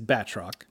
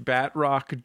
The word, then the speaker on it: Batroc: Well, first off, I hate to correct a pronunciation, but it is Batroc. – I'm sorry. – I think this might give you a clue as to who wanted him in the book. – It's pronounced Batroc, Jordan, but you might know it as Batcore.